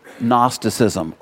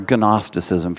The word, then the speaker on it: Gnosticism: Gnosticism, or Gnosticism, if you want to pronounce the G there.